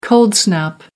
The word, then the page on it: cold-snap: American English